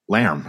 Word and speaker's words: Larm: But Bob checked me the other week about LARM, which apparently I've been saying incorrectly for the last 2 decades. What is the correct pronunciation of LARM? – Lamb, isn't it? – It's supposed to be lamb, isn't it? – It's like a lamb. I've been saying LARM for 20 years.